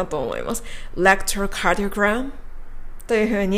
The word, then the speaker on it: electrocardiogram: では、ここまででですね、まず、 electrocardiogram と 3 つ山を書いてこの単語を発音するということを理解していただけたかと思います。